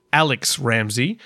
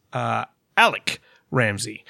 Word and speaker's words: Alex Ramsey: His family got in touch to tell us that it, in fact, is Alec Ramsey. It's spelled Alex, but they're from Scottish ancestry, and so he was always in life called Alec Ramsey.